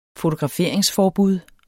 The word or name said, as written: fotograferingsforbud